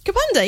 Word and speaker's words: Kapunda: which I'm going to pronounce wrong, Kapunda? – Kapunda, yeah, you did it right. Kapunda. Well done.